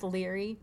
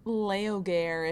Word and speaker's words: Laoghaire: And I don't understand how her name is spelled. It's like L-A-O-G-H-A-I-R-E. – But it looks like it's pronounced Leary. – Leogare, if you're like trying to pronounce it in English, even though it's a Scottish name.